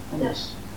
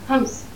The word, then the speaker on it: Homs: And by about 1500 BC, a line was drawn through Homs, is that how you pronounce the name? – Homs. – Homs, in Syria, between the Hittites in the north, who were the Anatolian power, and the Egyptians in the south.